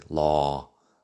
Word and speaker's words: law: Debería mencionar la pronunciación de la palabra law, que es la O larga, así, law, de derecho, o de ley también.